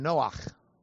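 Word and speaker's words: Noach (נֹחַ): You would probably want to pronounce his name Noach in Hebrew, okay? N-C-H.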